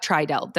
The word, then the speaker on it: Tri Delt: You were a member of Tri Delt. Did I say it right? Yes.